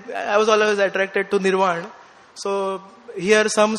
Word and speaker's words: Nirvana: So Americans, they pronounce it, they don't pronounce Nirvana, they say Nirvana, like that.